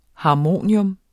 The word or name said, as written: HARMONIUMS